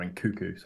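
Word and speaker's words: Nkunku: They're really lacking a forward, and they've got Nkunu coming in. So it's like, or Nkuku, sorry.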